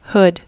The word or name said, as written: hood